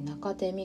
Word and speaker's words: 中手: 中手みかんというちょっと独特な字を書くんです読み方するんですけど真ん中の中に生きると書いて中手と読みます